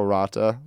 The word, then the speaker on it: Ranta: Bob Mosto's group is starting to come on, and you see Ratnan, what is it, Ranta? – Sampo Ranta, yep.